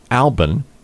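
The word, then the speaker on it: Alben: Albin in the Hipmunks. You notice how I carefully pronounce that. It's A-L-B-E-N, because that's one of their guys' names.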